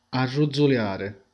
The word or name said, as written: arrotolare